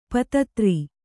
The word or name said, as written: ಪತ್ತೆದಾರ